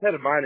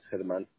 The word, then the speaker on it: Hedemann: Yeah, it's Hedemann. It's pronounced like, it's still like German, but it's pronounced Hedemann.